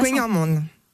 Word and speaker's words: kouign-amann: Comment ça se prononce que nous on dit kouign-amann, mais c'est pas kouign-amann. – Vous le dites bien.